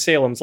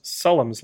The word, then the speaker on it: Salem's: Toby Hooper had just done the TV movie of Salem's Lot, which is actually pronounced Sullum's Lot, short for Jerusalem's Lot.